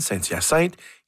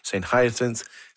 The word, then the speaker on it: Saint-Hyacinthe: The guard with whom I checked and asked my destination, I told him using the French pronunciation, Saint-Hyacinthe. He didn't understand. I finally reverted to the West Island English pronunciation, St. Hyacinth, and says that he understood.